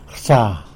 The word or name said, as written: rsa